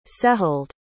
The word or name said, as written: sehild